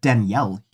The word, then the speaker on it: Danielle: It's said Danielle here, like spelled with an E, not like with an A at the end. So I wouldn't assume it's Daniela.